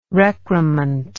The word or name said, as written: recrement